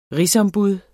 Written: RIGSOMBUD

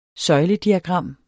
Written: søjlediagram

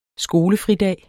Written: skolefridag

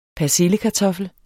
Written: persillekartoffel

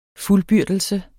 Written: fuldbyrdelse

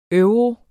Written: EUROS

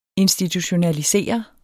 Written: institutionalisere

